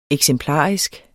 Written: eksemplarisk

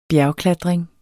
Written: bjergklatring

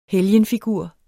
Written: helgenfigur

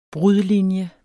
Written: brudlinje

